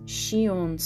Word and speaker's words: Sion: The director's name is pronounced Shion Sono, with Shion being the on readings of the kanji for child, she, and warm, on.